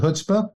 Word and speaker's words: chutzpah: I believe in the concept of chutzpah, or chutzpah as I pronounce it. Other people pronounce it chutzpah, because it contributes to people's success, because it enables them or gives them the courage to stretch boundaries.